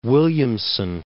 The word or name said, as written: Williamson, Mount